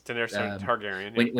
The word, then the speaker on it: Targaryen: Daenerys Targaryen.